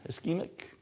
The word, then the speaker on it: ischemic: The number one most evil and dreaded disease is ischemic heart disease. Am I saying that right? Yeah, ischemic, pretty close.